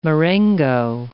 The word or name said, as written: marengo